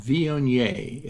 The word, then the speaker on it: Viognier: And that is a wine called Viognier. It's spelled V-I-O-G-N-I-E-R.